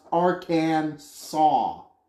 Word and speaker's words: Arkansas: Arkansas must be pronounced Arkansas. Ar- It literally spells it out. A R K A N S A W.